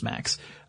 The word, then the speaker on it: MMACS: Next, we have the Maintenance, Mechanical, Arm, and Crew Systems Officer, or MAX, M-M-A-C-S, but it's pronounced MAX.